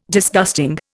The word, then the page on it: Disgusting: Indian